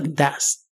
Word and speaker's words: does: Se le añade también una ES, pero se pronuncia does.